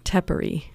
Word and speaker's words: Tepary: Tepary. – Tepary.